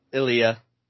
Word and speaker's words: Ilya: Ilya.